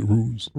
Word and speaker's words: ruse: And once I saw that it was consistent, it wasn't just a rouge, then, did I say that right? Ruse. – Good job. Ruse. You got it.